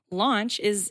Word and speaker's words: Lantzsch: Launch seems like Lanch, or a lot of people pronounce it wrong when you look at it, but it's really easy to remember.